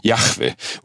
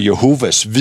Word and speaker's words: Jahve: De har jo det navn med, fordi de mener faktisk, at Jehova, ikke også, er det korrekte udtale. Det er det bare ikke. Det er Jahve, og det er konsonanter, og det betyder jeg er.